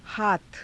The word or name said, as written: haatha